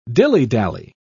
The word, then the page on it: dillydally: British English